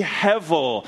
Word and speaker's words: hevel: Pronounced with, or written with a B, pronounced with a V, hevel.